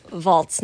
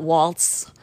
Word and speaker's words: valse: And going back to the 13th century when the valse was invented, it's pronounced valse, not waltz, because waltz is English-sized, mm-hmm.